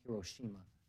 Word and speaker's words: Hiroshima: Hiroshima is actually pronounced Hiroshima.